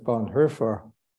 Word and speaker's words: Bonhoeffer: Actually, it's Bonhoeffer because it's O-E, and that's umlaut it, so you say Bonhoeffer.